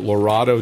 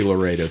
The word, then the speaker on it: Laredo: what did the people in the UK say? Tornado? Is it Lorado Tornado? I mean, it's got to be Laredo Tornado, right? It rhymes.